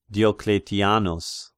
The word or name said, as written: Diocletianus